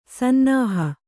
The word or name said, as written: ಸನ್ನಹ